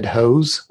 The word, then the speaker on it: Hoos: I'm at David Hose. Last name is spelled H-O-O-S. Don't know why it's pronounced that way, but that's just what my parents told me.